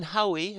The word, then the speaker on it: Haüy: In 1784, French calligraphy professor Valentin Haüy, is spelled H-A-U-Y, Valentin Haüy, this is in French, in France, he opened the first school for the blind in Paris.